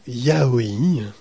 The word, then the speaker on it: yaoi: Matt sera là pour nous parler du yaoi. On finira sur... – Ça se prononce bien comme ça, ouais.